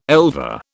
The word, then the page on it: elver: British English